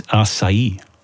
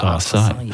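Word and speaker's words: açaí: And for the record, the correct way to say it is acai. Not our side.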